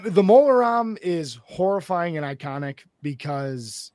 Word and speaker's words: Mola Ram: Yep, this round is Voldemort versus Mola Ram. Like CD-ROM, but apparently it's spelled Ram.